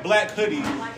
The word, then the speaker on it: Blac Hoodee: And while you're on Instagram, you can actually follow my band Blac Hoodee. It's not exactly how it's spelled, it's B L A C H O O D E E.